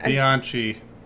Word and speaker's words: Bianchi: Kenneth Bianchi. Or Bianchi. I can't... – Bianchi. – Kevin Bianchi, that's yeah. How you pronounce it? – Mm-hmm.